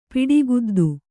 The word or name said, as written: ಪಿಡಿಗುದ್ದು